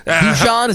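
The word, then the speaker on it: Dušan: Dusan, is that how you say it? – Yeah, douche.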